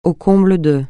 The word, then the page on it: au comble de: francais